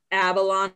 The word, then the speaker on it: Avalon: But before Varsity Blondes was The Pretty Picture, which I think they got rid of Peter Avalon's like librarian character now. So that's okay. I was like, I'm not even sure I'm saying this right, where it's like Avalon and Benoni. – Avalon, yeah.